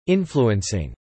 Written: influencing

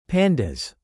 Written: pandas

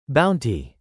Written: Bounty

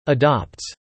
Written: adopts

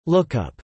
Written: lookup

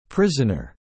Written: prisoner

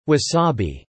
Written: wasabi

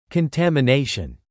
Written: contamination

